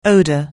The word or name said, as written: odour